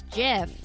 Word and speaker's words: GIF: Well, because I remember that the guy or whoever invented the Jeff said that's how you pronounce it. Like, Jeff. – Isn't it after his name or something? – I think so. I can't remember, but it was something. So that was for sure. But then there are like English language experts who come out and say like, well, no, but these three letters in this combination, it has to be GIF, so it should be GIF.